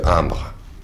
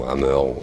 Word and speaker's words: Hamr: Au niveau de la mythologie scandinave, ce qu'on appelle Hamr, H-A-M-R, Hamr, ou Hammer, il y a plein de prononciations différentes, bref.